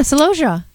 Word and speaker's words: celosia: Is it celosia? They're these. – Yeah, celosia.